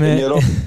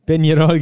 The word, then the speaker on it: Peñarol: Dein Club Atletico Peñarol, ich hoffe, ich habe das richtig ausgesprochen mit diesem Enje. Ähm, Peñarol. – Peñarol, genau.